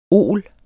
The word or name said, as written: ol